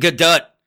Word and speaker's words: Gadot: And if you think that I'm pronouncing that wrong by saying Gadot, go online and check it out, because it shocked the hell out of me. And that's really how you pronounce it.